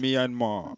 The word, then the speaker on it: Myanmar: Myanmar, if I haven't pronounced. – Myanmar.